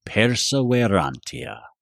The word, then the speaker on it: Perseverantia: Perseverantia.